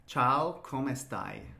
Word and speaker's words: Ciao, come stai: Ciao, come stai. – Ciao, come stai.